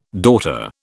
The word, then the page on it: daughter: British English